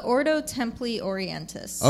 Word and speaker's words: Ordo Templi Orientis: Yes, that's the Ordo Templi Orientis.